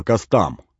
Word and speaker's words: костам: «Мы предпочитаем перейти скорее в ласты, ударение на «ы», чем прогнуться по костам, ударение на «а», и тем самым позволить аутсорсить наш ресурс без достижения заложенного профита».